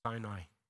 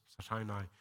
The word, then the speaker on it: Sinai: And then 50 days after that, after when they left Egypt, they came to Mount Sinai. Did I say that right? Sinai. Yes, Sinai.